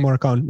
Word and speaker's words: Morricone: Yeah, Ennio Morricone.